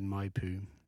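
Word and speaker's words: Maipú: Maybe it's like a Majpu. – No, I just looked at my pronunciation, Majpu.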